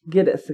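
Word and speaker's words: Gittes: Jake Gillis. – Giddis, excuse me.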